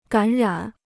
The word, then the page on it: 感染: gǎn rǎn